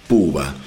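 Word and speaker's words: Poobah: Puba, para los que les interese, se escribe P o o b larga a h. Puba.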